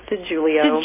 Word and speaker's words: DeJulio: It's actually, it looks exactly like you said it, but it's actually pronounced DeJulio.